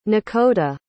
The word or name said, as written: nekoda